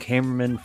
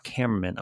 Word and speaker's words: Kamerman: You're about to go for camera, but then it's Kamerman, you know? So just think of it like that, camera.